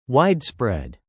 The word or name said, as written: widespread